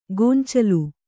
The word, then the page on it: ಗೊಂಚಲು: goncalu